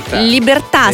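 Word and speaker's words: Libertà: Вы знаете, мне повезло, и в своей жизни я исполнил три композиции, которые, ударение в которые заканчиваются на «а», и ударение падает на эту последнюю «а». Это «Феличита», «Счастье», «Либерта», «Свобода» и «Чисара», «Будущее».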